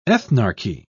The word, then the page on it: ethnarchy: British English